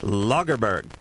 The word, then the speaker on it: Lagerberg: Bengt Lagerberg.